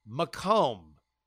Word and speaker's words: Macomb: And then it hit me, what I had pronounced as Macomb, I guess it was like Macon, Georgia for me, is indeed pronounced Macomb. Said much like Macomb, thanks to the inundation of the French in the early days of colonization.